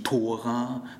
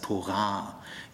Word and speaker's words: Tora: Sag bitte nicht Sabbat, äh, immer betonen auf der zweiten Silbe, Schabbat sagt bitte nicht Tora, sondern es heißt Torah.